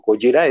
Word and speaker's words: Gojira: Gojira es el nombre.